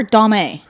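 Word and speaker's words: Domme: Is it, for a female, is it pronounced Dom or Dom-A? – Yes. – It depends on who you ask.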